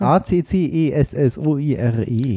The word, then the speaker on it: Accessoire: Wir buchstaben A C C E S S O I R E. – Accessoire heißt es mit einem K ausgesprochen.